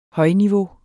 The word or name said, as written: højniveau